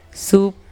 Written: Sup

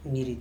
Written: mirit

